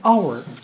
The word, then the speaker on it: hour: an hour 这里又很奇怪了， h 不是子音，为什么要用 an 呢？因为 h 不发音，所以我们说 an hour，后面直接接 o，所以反而前面要用 am，这是第一点。